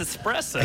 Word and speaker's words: espresso: I say so I put it like an X in it. – That's a tendency, but I didn't learn how to properly pronounce it until that scene in Young Frankenstein. – Exactly. It's espresso.